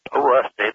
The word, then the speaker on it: Olustee: It's pronounced Olustee.